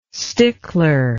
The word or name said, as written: stickler